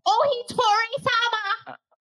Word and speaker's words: Ohitori-sama: Ohitori-sama!